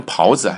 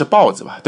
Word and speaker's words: 刨子: 豹子就是木工啊，那个豹子，你别念成袍子啊，应该是豹子吧，对吧？